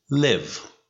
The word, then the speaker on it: live: ببین زندگی کردن رو باید تلفظ کنی live. و ترک کردن رو باید بگی leave.